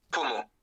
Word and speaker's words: pommeau: Comment tu l'as dit, le pommeau? – Donc, pommeau.